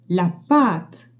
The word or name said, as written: pate